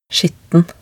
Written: skitten

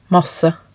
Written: masse

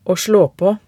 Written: å slå på

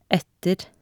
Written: etter

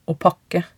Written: å pakke

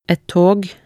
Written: et tog

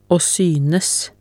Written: å synes

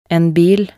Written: en bil

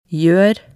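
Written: gjør

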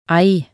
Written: ei

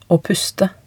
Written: å puste

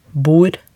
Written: bor

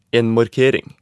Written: en markering

